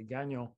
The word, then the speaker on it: Gagnon: And I always appreciate anyone who can actually pronounce our last name because it's never easy for anyone who speaks English, but certainly French know how to say Gagnon.